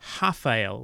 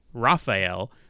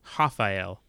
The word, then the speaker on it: Rafael: I've been told that it's pronounced Rafael, like a H-A-F-A. So for those who are trying to pronounce it Rafael, do the same thing that you would do with Juan instead of Ruan.